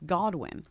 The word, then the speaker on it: Godwin: She's not more famous than Stranger Things, but I feel like more people know Hannah Goodwin's name than Noah Schnapp's. – No. Also, you're saying it wrong. It's Godwin.